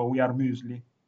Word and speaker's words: Müesli: Spero di averlo detto giusto, We Are Muesli alla grande, grande.